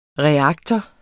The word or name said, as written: reaktor